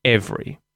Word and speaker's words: every: So, the more natural way of pronouncing this word would be using two syllables. Every.